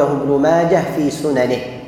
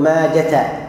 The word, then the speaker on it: ماجه: أخرجه ابن ماجه يعني في سننه، وكلمة ماجه تنطق بإسكان الهاء وقفا ووصلا، يعني نقول أخرجه ابن ماجه في سننه، بعض الناس يقول أخرجه ابن ماجة بالتاء وهذا خطأ. الذي صححه العلماء أنه ينطق بإسكان الهاء وصلا ووقفا.